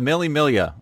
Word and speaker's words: Mille Miglia: The Mille Miglia, is that right? – Yeah, that was perfect.